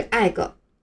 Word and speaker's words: egg: 注意这个单词的读音不是 egg，尾音的 g，发音比较轻，不要特意的去强调它。